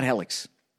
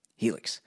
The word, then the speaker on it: Helix: Helix. Not Helix. Helix. Look, I know some of you are sleeping on saggy old mattresses at night, and you deserve better than that. Give yourself an upgrade with Helix Sleep.